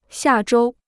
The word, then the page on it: 下周: xià zhōu